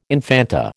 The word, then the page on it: infanta: American English